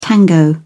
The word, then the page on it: tango: British English